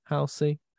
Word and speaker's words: Halsey: Halsey. – I think it's Halsey. – You look, add a bit of a z to it.